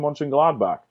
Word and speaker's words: Mönchengladbach: I'm going with the New York Giants and Borussia Mönchengladbach. Staying in your current country of Germany, staying away from the Premier League this week. – Top pronunciation there, by the way. Top pronunciation.